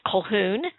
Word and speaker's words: Colquhoun: That's how it's not spelled like that. It's like with a Q and all. It's Colquhoun, who was a guide, theoretically.